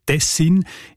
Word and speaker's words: Tessin: Und auch im Hochdeutschen sieht man Tessin. Die Variante mit der Betonung auf der ersten Silbe und mit kurzem «i», also «dessin», ist seltener. Im